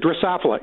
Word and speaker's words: Drosophila: These, I think I'm saying that right, Desphelia fly? – Yeah, they're really Drosophila, and it is a fruit fly.